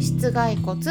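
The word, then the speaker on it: 膝蓋骨: で室外骨って読むん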